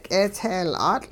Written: k'eetseeyl'aatL'

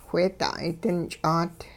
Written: wheeda'ay dinch'aat

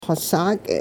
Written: xosaak'

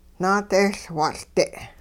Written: naadehLwatL'-de'

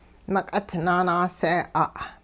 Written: meq'it naanaasee'a'

